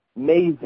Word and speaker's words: Mazen: M-A-Z-E-N. Mazen.